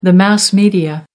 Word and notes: mass media: American English

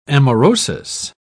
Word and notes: amaurosis: British English

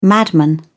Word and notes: madman: British English

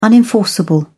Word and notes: unenforceable: British English

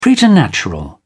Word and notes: preternatural: British English

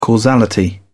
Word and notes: causality: British English

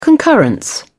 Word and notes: concurrence: British English

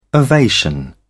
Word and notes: ovation: British English

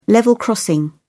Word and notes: level crossing: British English

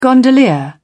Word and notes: gondolier: British English

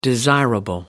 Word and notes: desirable: American English

